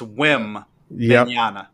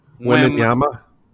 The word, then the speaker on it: Wembanyama: I think it's Wembanyama.